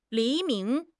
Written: 黎明

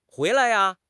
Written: hui2 lai5

